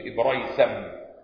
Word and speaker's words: إبريسم: قال قوله عمامة خز، قال ابن الأثير الخز ثياب تنسج من صوف وإبريسم، تنسج من صوف وإبريسم، بينطق هكذا إب بتسكين الباء وفتح الراء براء، الياء ساكنة، السين مفتوحة، إبريسم.